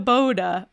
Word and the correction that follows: Baba: Baba. – Baba.